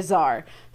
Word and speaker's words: czar: Which, as an English speaker to me, is very funny because we do not pronounce the T in czar at all. We just say czar.